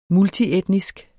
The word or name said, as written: multietnisk